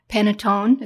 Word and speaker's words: panettone: There's cookies, cakes, frostings, meringues, an Italian panettone.